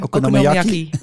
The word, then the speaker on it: Okonomiyaki: Okonomiyaki.